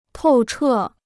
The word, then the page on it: 透彻: tòu chè